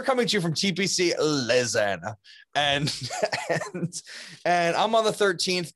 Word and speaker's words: Louisiana: How do you say it? – Louisiana. – No, it's Louisiana. – Louisiana.